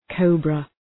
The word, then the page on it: cobra: 'kəʋbrə